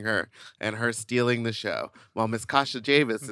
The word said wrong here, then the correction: Kasha Davis: She goes, did you say her name was Kasha Jamis? – Kasha Davis. I think I was just slurring.